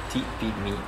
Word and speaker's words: Tipimi: Pour une fois, ça s'écrit comme ça, se prononce Tipimi.